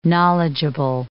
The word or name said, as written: knowledgeable